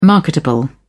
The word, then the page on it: marketable: British English